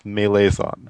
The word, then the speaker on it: Malazan: I think it's pronounced Malazan, I don't believe in official pronunciation.